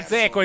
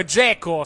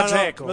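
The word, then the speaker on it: Džeko: Non è Zeko, è Dzeko. Si pronuncia Dzeko.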